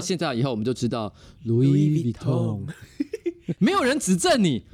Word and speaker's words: Louis Vuitton: 对啊，因为我那时候我记得我应该是念 Louis Vuitton，很多人就会跟我解释，就是说、嗯、第一个 S 是不发音的，哦、真的吗？就是 Louis，然后呢念起来应该是 Vuitton。 – Vuitton，哎，你不觉得我自己知道我自己是乱念，所以我我念得很心虚啦。嗯，但你一发音变成是这样 Louis Vuitton。